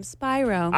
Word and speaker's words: Spiro: Spyro. – Yes. They said Sienna Spiro. – I've been saying it wrong this whole time. Spyro.